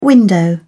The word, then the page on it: window: British English